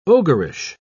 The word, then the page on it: ogreish: British English